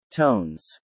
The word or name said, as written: Tones